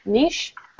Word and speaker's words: niche: That's Nintendo's niche. I'm saying that right? – Yeah.